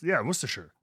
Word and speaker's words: Worcestershire: How do you pronounce the sauce that is Liam Perrins? – That is what? Oh, is it Worcestershire? – Yeah, see, it's got it. – Yeah, right? Cool. That's Worcestershire.